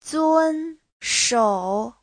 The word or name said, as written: zun1shou3